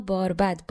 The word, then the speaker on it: باربد: بنابر نوشته های لغتنامه علامه دهخدا اسمش رو دو جور میشه تلفظ کرد هم با زمه که در حال حاضر معروفتره و هم با فتحه اما باربد با فتحه قدیمیتر و معتبرتره